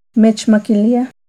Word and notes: Mechmaquilia: Náhuatl

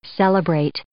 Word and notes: celebrate: verb

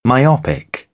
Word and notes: myopic: British English; ɒ

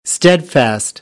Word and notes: steadfast: American English; æ

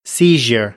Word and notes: seizure: American English

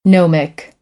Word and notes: gnomic: American English; oʊ - əʊ

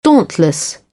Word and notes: dauntless: British English; ɔː

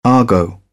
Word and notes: argot: British English

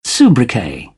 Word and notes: sobriquet: British English

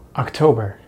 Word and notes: October: American English

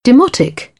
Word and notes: demotic: British English; t

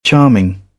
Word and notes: charming: British English; ɑː

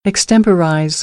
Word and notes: extemporize: American English; ɚ - əɹ

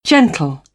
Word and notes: gentle: British English; t

